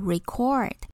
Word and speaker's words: record: 要注意这个词当动词的时候，发音会变成第二音节，变成 record，意思是记录下来或是录制什么东西。